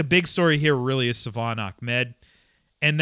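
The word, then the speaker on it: Salvon: So anyway, a lot of people called him Salvin yesterday. Savon.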